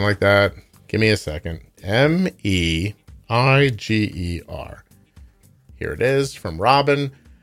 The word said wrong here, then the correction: Meijer: Just a heads up, Meijer is pronounced Meyer, and it's a Michigan-based department store, groceries, clothing, and home goods, and a great pharmacy.